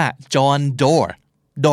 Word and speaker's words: Doerr: D O E R R เพราะฉะนั้นเราก็จะอ่านว่า Doer เพราะอ่านยังไงมันก็โดเออร์ถูกไหมแต่จริงๆแล้วชื่อนี้อ่านออกเสียงว่าจอห์นดอร์ด